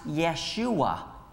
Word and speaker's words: Yeshua: His name means salvation, and it's pronounced Yeshua in Hebrew.